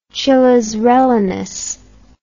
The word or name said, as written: chiles rellenos